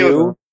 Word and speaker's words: Fedor: Fyodor? Fedor? It's like few. – Fyodor. – Like, there are not a lot of doors. There are few door.